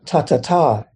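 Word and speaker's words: tathatā: Suchness, ta-ta-ta, is how it's pronounced in Sanskrit, a lovely word, ta-ta-ta.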